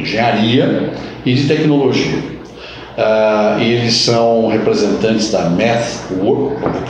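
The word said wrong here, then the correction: MathWorks: tentar pronunciar corretamente, MathWork, né? Works, desculpa. E,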